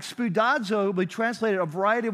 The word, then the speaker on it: spoudazo: The word in Greek is the word spoudazo. I'm going to spell it for you because a lot of you like to write these things down. But spoudazo is S-P-O-U-D-A-Z-O, spoudazo.